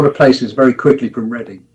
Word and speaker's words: Reading: Reading, yes. All right. – Yeah, well, it's pronounced Reading in England.